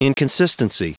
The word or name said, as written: inconsistency